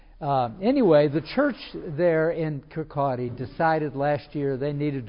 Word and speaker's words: Kirkcaldy: He preaches in Kirkcaldy, K-I-R-K-C-A-L-D-Y. It looks like Kirk, the word for church. Caldy, C-A-L-D-Y, but pronounced by the Scots, Kirkcaldy.